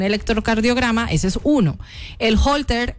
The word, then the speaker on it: Holter: Me están diciendo que el examen que yo me quiero hacer se llama Holter. Así se pronuncia. Se escribe Holter con H, O, o sea, H-O-L-T-E-R.